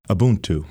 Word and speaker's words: Ubuntu: How do you pronounce the word again? – Ubuntu. – Oh, that's beautiful. – Yeah, it's a Zulu term.